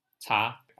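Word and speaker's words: cha (茶): So pronunciation, cha, as the Cantonese said, Cha spread from Canton to the Europe.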